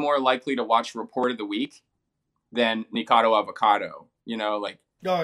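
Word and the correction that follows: Nikocado: Am I saying his name right? – Nico Cotto. – Nico Cotto Avocado.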